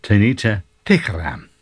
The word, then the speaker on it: Tanita Tikaram: Now, I remember when that first came out in the early 80s that everybody used to have trouble pronouncing her name, but I used to practice, and it's Tanita Tikaram.